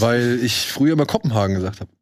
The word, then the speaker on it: Kopenhagen: Ja, ne, Also Kopenhagen Cowboy mit langem O. – Genau, Kopenhagen Cowboy.